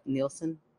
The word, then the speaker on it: Neeson: Liam Neeson? – That's right.